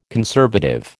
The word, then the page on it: conservative: American English